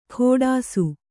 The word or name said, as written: ಖೋಡಾಸು